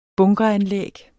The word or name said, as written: bunkeranlæg